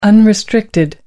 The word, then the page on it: unrestricted: American English